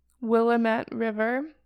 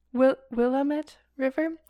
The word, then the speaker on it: Willamette: The tunnels connected the city's oldest buildings with the, I might pronounce this wrong, Willamette River. Willamette? Not quite. – Okay. – Willamette River, so that goods could be brought in from the ships to the city center.